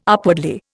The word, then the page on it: upwardly: Indian